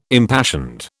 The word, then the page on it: impassioned: British English